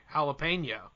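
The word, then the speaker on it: jalapeño: Is that how y'all pronounce it down there? – Jalapeno. – Jalapeno? – Jalapeno. – That's weird. – How do you say it? It's really, correctly, jalapeno.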